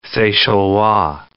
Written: Seychellois

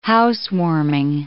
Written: housewarming

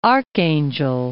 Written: Gulf of Archangel

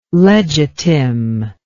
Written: legitim

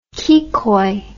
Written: kikoi

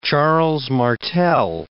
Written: Charles Martel

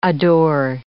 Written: adore

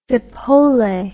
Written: zeppole

